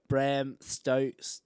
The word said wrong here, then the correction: Stoker: How do you say that name? – Bram Stoker.